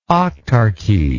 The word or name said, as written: octarchy